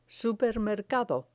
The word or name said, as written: supermercado